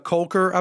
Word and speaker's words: Kolker: Well, for the most part, the movie's following Chloe, Lexi Kolker, I believe is how you pronounce it. – Yep.